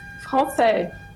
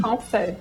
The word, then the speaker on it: Francé: Como é que pronuncia seu sobrenome, doutora? – Francé. – Francé? Assim? Olha aí. – Francé.